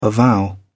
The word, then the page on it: avow: British English